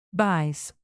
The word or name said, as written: buys